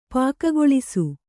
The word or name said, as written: ಪೊಗೆಗೊಳಿಸು